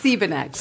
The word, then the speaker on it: Siebeneck: Just a little. It's Siebeneck, but you're Siebeneck. Close.